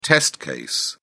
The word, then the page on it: test case: British English